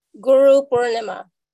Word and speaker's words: Guru Purnima: Guru Purnima.